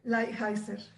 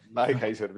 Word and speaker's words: Lighthizer: a ver si me lo aprendí, Lighthizer. – Lighthizer. – Lighthizer, bien, ya, ya lo pude pronunciar.